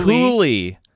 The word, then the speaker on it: Cuylle: Isn't that the San Jose goaltender that Sorry, spell this man's name again. – C-U-Y-L-L-E. – Cooley? – Cooley. Is that really how you pronounce it? I would never have guessed that.